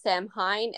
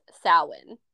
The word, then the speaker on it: Samhain: And I think his name is kind of related to the name of Halloween, except it's not actually pronounced Samhain, it's pronounced Samhain. – Samhain.